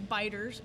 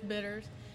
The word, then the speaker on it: bitters: A lot of people pronounce it, I've found that a lot of people pronounce it as Biters, but no, we're not Biters around here, it's Bitters.